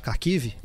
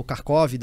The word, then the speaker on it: Kharkiv: Por exemplo, a cidade agora que está sob escombros, Kharkiv, ou Kharkov, dependendo da, da pronúncia.